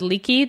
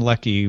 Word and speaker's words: Leckie: I was pronouncing her name Leckie, and then someone said Leakey the other day, and so now I don't know what to do. – I believe she told me Anne Leckie when I saw her at the Nebulas.